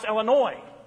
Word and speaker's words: Illinois: And I'm used to that because I lived in Illinois, and how do people often pronounce Illinois? Illinois. Okay, the S is silent, as it is in Arkansas. There's no S at the end that you hear.